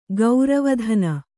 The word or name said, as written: ಗರುವತನ